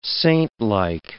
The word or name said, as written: saintlike